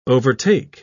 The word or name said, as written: overtake